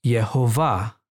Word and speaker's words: Yehovah: And when we insert the Hebrew letter Vav, or the V sound, we have the pronunciation Yehovah.